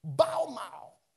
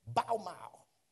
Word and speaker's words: bamah: Baumau. It is actually spelled B-A-M-A-H, but it's pronounced B-A-W apostrophe M-A-W, Baumau.